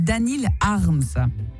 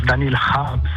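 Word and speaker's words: Daniil Kharms: Daniil Harms. – Tout à fait, même on le prononce Daniil Harms. – Harms. – C'était la prononciation à la russe.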